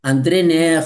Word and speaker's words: Neher: André Nair se escribe Neher.